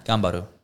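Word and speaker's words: Ganbaru (頑張る): How do you say it? – Ganbaru. Means do your best. – No. Ganbaru.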